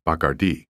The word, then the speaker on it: Bacardi: I have used Spanish spellings for all names in this book, with the notable exception of Bacardi, which in Spanish is accented on the final syllable and pronounced Bacardi.